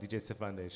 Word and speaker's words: Stefande: DJ Stefande.